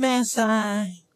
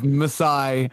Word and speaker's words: Masai: That's now it's the mispronunciation of Maasai, I guess by a computer, Maasai. It's weird. You know, I think the computer can pronounce other harder things. But yeah, Maasai.